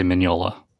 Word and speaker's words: Mignola: I say Mignola.